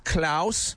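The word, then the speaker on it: Klaos: Yes, Klaus. – How do you spell that? – It's K-L-A-O-S.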